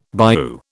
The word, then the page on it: bayou: British English